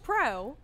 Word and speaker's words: Preau: And it's and it's been kind of neat with the name, with the last name like Pro. I mean, very, very typical. P-R-E-A-U is how we spell it.